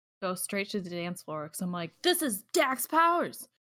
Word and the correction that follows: Dex: Did I say that right? Dax? – Dex. – I'm like, Dex Powers!